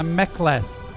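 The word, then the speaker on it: mek'leth: Darren George, a Klingon woman wielding a Mekleth.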